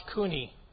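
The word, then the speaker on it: kuni (国): And this is pronounced kuni.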